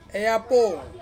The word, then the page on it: apo: Ansus